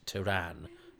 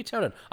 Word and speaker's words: Terran: Isn't it odd that Tom Baker pronounces Terran, as in of Earth, as Turan? I bet somebody said, Tom, shouldn't it be Terran?